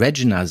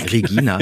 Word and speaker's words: Regina: Und das ist wahrscheinlich auch der Grund, warum hier alle nicht Regina sagen, sondern genau. Regina, deutsch ausgesprochen.